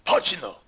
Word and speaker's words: Pacino: It's pronounced Pacino. – Pacino. – Pacino. Spelled like it sounds with an extra H.